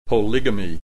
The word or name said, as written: polygamy